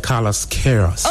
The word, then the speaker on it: Carlos Queiroz: I like how you pronounce the Carlos Queiroz. – Oh, you got it right.